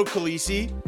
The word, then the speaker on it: Calisi: I'm Vito Calisi. With me is Dad Jonathan Barron. Why are you shaking your head? Is it because I've already said a different way of how to say my name? – Yeah, I was expecting Calise.